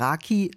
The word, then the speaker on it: Rakı: Raki, eigentlich Rake ausgesprochen, ist das Nationalgetränk der Türkei.